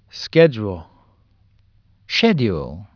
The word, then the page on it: schedule: American English; British English